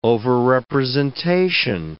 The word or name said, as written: overrepresentation